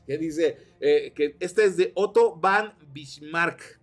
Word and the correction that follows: Otto von Bismarck: Espero haberlo pronunciado bien. Otto von Bismarck.